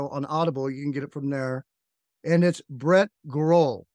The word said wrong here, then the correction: Grau: Did I say that right? – No, it's Grau. – Grau without the L. Brett that, Grau.